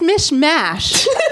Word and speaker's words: mishmash: It's Mish Mash. I'm from Delaware. That's how we pronounce it there. But my scientific explanation is that mishmash is like you're mixing and mashing stuff together. – Yes. – And it's mash. It's like mashed potatoes.